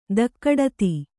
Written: ತಗಡತಿ

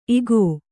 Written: ಇಕೊ

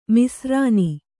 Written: ಮಿಸ್ರಾನಿ

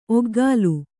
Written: ಒಕಲು

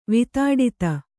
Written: ವಿತಾಡಿತ

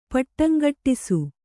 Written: ಪಟ್ಟಂಗಟ್ಟಿಸು

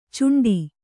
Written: ಚುಂಟಿ

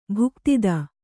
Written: ಭುಕ್ತಿದ

